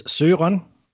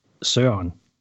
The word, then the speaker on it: Søren: Well, my first name has two pronunciations. There's a formal and an informal. – Oh. – To make matters even more interesting. The formal way is Søren, and the informal way is Søren, where you kind of not say the R.